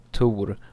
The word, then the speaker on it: Thor: Where every man is named Thor. – Every other man, at least. And... well, he pronounced it Tor, but, well, same thing.